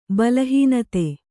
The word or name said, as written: ಬಲಹೀನತೆ